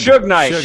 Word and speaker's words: Suge Knight: Surge Knight. How do you say that? – Suge Knight. – Damn it. I always forget that.